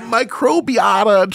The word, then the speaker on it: microbiota: Fecal microbiota transplantation.